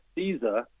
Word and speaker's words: SZA: And by the way, as we've been talking here, I did see that CISA, who pronounces her or spells her name S-Z-A.